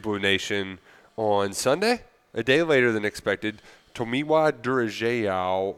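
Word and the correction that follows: Durojaiye: Oh, man, I already – Tomiwa Durajai, I think it is. – There we go. Yeah. That was a great Oklahoma pronunciation.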